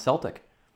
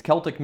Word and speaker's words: Celtic: It is, but the Scottish football team is pronounced Celtic. So I guess it just depends. I guess one of those things where it's acceptable either way.